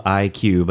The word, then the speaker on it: Eye Cue: So let's talk about Macedonia, this group called IQ, but it's spelled E-Y-E-C-U-E, which I love.